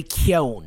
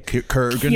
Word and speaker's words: Keoghan: I look so freaking big next to Barry Keoghan. How the fuck do you say his name? – Kurgan. – Keoghan. Keoghan. It's Irish. I looked it up.